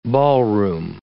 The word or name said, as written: ballroom